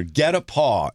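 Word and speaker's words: guet-apens: It's G U E T A P E N S. But it's pronounced get a paw.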